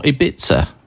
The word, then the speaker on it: Ibiza: And we're going to Ibiza. Or Ibiza, as Kim Sassabone, the lead singer of the Venga Boys, says. An unusual pronunciation in English.